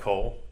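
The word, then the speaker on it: kol (כל): But no, it's like kol, you know, like Cole Egbert. I mean, it's pronounced the exact same way.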